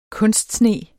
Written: kunstsne

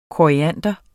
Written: koriander

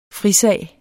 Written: frisag

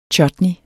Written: chutney

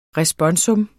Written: RESPONSUMS

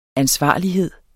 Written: ansvarlighed

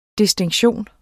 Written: DISTINKTIONEN

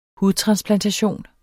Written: hudtransplantation

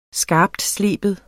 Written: skarptsleben

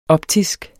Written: optisk

